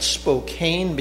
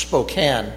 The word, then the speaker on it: Spokane: Some people call that Spokane because it's spelled A-N-E. But we live there, or I lived there as a child, and it's pronounced Spokane by all the people who live there.